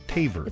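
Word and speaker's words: Tavares: T-A-V-R-E-S. I know it looks like Tavres, but it's Taver. – It's Tavers? – Like Fitre. – Yeah, think of a tavern, and that's how you pronounce it.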